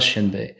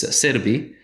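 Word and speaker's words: Xianbei: Again, the original pronunciation of that name is not Shenbei. It's Serbi.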